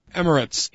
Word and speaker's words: Emirates: Emirates.